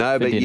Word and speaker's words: Nobu: Nobu, yes. I have taught you how to pronounce it correctly.